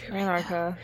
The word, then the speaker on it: Anarcha: oh, I feel like I'm saying it wrong, Anarchy, Anarcha, Anarcha. – Anarchy is what it sounded like.